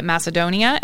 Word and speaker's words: Macedonia: She and her son, Max, lived in nearby Macedonia.